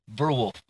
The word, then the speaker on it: Verwolf: How you say your name? Verwolf. Verwolf? No, Ver. Verwolf? There you go, Verwolf.